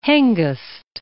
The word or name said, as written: Hengest